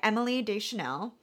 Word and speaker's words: Deschanel: Played by, well, her character name is Temperance Brennan, and am I saying that right? Yeah, Temperance Brennan, played by Emily Deschanel, right? – Yeah, as far as I know, that's how that's pronounced.